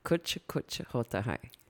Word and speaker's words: Kuch Kuch Hota Hai: Kuch kuch hota hai.